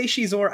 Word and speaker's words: Xizor: I say Xizor, I think that's the pronunciation, I googled it. His name is spelled X-I-Z-O-R, and arguing about how that name is pronounced is an age-old pastime of Star Wars fans.